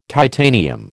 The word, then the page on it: titanium: American English